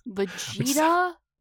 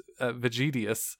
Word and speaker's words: Vegetius: Vegeta? – It sounds like Vegeta, but it's Vegetius. If it's spelled very closely to Vegeta, honestly. It's just I-U-S instead of A.